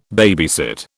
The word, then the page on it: babysit: British English